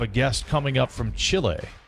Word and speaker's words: Chile: She's with Team Canada in the three-on-three version of Team Canada down in Chile. And you said it correctly there.